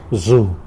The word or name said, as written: zu